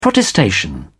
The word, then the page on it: protestation: British English